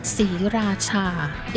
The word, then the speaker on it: Sriracha: Sriracha. Even we spell it out S R I, but the R is not pronounced. It's something in Thai language that we call is a false cluster.